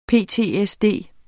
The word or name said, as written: PTSD